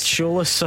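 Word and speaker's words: Tziolis: Alexandros Ziolis. – Cholis, I think. Yep, Ziolis. Not bad.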